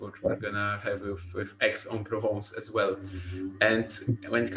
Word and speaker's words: Aix-en-Provence: And I checked the pronunciation, and it's actually Aix-en-Provence. – Aix-en-Provence. – So yeah, I thought that the first three letters are gonna be pronounced differently, but it turns out it's Aix-en-Provence.